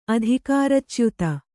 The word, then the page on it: ಅಧಿಕಾರಚ್ಯುತಿ: adhikāracyuti